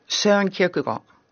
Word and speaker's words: Søren Kierkegaard: Søren Kierkegaard.